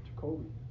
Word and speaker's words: Jacoby: Can you pronounce his goddamn name right? – Jacoby. – Jacoby. – There's no L in there.